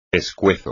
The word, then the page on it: pescuezo: Spanish